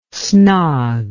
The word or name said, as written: snog